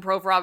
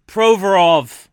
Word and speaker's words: Provorov: His name is Ivan Provorov. He's Russian. – Oh, my God. – Well, we don't pronounce it Provorov. – Yeah, because I'm from here. I don't say anything that way because it sounds asinine. – And in fairness, he specifically said he wanted to be called Provorov, not Provorov. Because we asked him, because that is the way you're supposed to pronounce it.